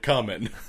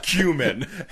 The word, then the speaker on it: cumin: Just imagine you're saying cumin, but add a Q. – I'm going to tell you right now, not calling it coming. So it's cumin.